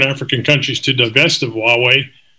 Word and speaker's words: Huawei: It sounds pronounced, Huawei.